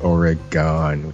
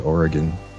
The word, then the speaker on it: Oregon: They said Oregon, which is not the way you say it. – How do you say it? – The way you said it, Oregon. – Oregon? Okay. Oh, I said it right. Yeah. I could say it like David Lynch, Oregon. – Some of the locals make a big deal about how you pronounce it.